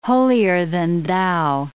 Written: holier-than-thou

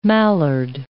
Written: mallard

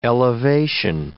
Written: elevation

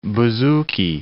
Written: bouzouki

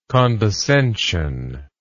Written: condescensively

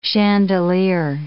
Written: chandelier

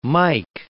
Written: mike

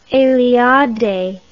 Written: eliade

